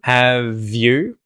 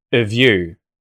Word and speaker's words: have you: We can remove that H through H deletion. Have you. Have you. And then we can de-stress the A vowel sound in av and turn it into a schwa. So you're going to hear of you.